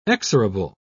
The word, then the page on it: exorable: British English